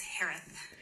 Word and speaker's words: Herath: Last name is pronounced like Harris with a lisp. It's Harith. H-E-R-A-T-H.